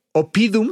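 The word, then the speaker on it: Oppidum: Por favor, o sea, si quieren darse un quemón, entre Opidium, Opidum, se, se escribe con doble P, Opidum, así como se oye.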